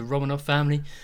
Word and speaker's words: Romanov: How'd you say it? Romanov family? – Romanov family? – Is that how you say it? – Yeah.